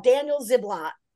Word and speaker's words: Ziblatt: I have Stephen Levitsky and I have Daniel Ziblatt.